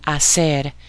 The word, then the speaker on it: hacer: For those of you that are first starting out in Spanish, one of your first main verbs will be hacer, and that's spelled H-A-C-E-R. It means to do or to make. So, hacer. It's literally pronounced as A-C-E-R.